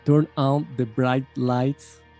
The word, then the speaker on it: Turn On the Bright Lights: Turn on the bright lights, así se pronuncia.